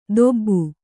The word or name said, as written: ದೊಬ್ಬು